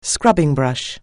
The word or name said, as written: scrubbing brush